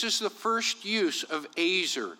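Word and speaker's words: azer (עזר): It's spelled E-Z-E-R. That would be how we would phonetically spell it. But we would pronounce it, that E, like an A, a long A, azer. But it's spelled like easier, which I find interesting.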